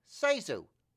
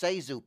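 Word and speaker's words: ZaSu: I do Joe Penner impressions and make quips about Zazu Pitts. – Sezu. – What? – Her name is pronounced Sezu Pitts.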